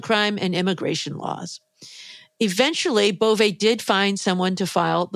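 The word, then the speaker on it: Bove: Acting Deputy Attorney General Emil Beauvais, we've now learned how to pronounce his name, it's Beauvais,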